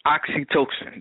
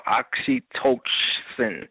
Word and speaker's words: oxytocin: And as far as I just recently learned that oxytocin, that's what it's called. I'm sorry, I'm pronouncing it wrong. It's called oxytocin.